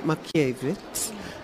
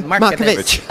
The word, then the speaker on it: Markievicz: The first woman to be elected to the British Parliament was Constance, I pronounce it Markievicz, I suspect correctly, because I've been practicing. – We have the Irish pronunciation, and it kind of goes to Markovitch.